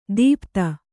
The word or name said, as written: ದೀಪ್ತ